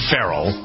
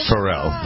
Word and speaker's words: Pharrell: Pharrell, I think he wants the accent on the second syllable. I used to say Pharrell, but no, I think it's Pharrell.